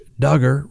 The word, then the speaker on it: Duggar: Lifelong Duggar family friend Bobby Holt took the stand and broke down in tears as she described how Josh Duggar, rhymes with hugger, confessed to his in his teens how he had inappropriately touched his sisters.